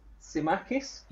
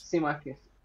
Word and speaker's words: Tsimikas: So I'm already not certain about how to say it, but I think it's Kostas Tsimikas. – Yeah, I think the T's silent. Yeah, I think you've nailed that. So congrats. – Tsimikas.